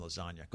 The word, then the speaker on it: lasagna: Real G's move, the silent G in lasagna.